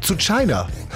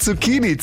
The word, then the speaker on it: Zucchini: Sowas wie zu China. Ja, statt Zucchini, zu China eben.